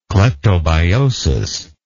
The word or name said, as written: cleptobiotic